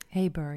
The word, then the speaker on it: Heber: Eh, zij was getrouwd met inderdaad Hebor, zeg ik dat goed? Heber, ja. Heber, ja.